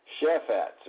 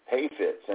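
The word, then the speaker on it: hafetz (חפץ): Now in Hebrew, that word for purpose is shephatz, or it's actually pronounced hafet, hafetz, and it means to delight or pleasure.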